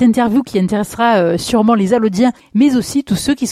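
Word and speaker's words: Allaudiens: Et il faut aussi préciser que on dit Allodiens et non pas Allochiens. – Oui, tout à fait. Allo s'écrit avec un CH à la fin. Alors, ça se prononce pas. Hein. Les gens de la région le savent. Quand les gens viennent de Paris, c'est des fois plus compliqué. Ils ont le réflexe de dire Alloche.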